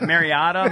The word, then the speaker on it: Mariota: If you start pronouncing his name correctly, then I'll get behind you. – What, Mariota? – Mariota. Mar, like bar.